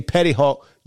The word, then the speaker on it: Petty Hawk: So when you say Petty Hawk, you spell it.